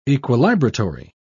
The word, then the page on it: equilibratory: British English